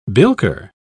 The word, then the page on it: bilker: British English